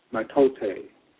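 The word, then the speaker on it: mitote: Your whole mind is a fog which the Toltecs call a metote. Pronounced Matote.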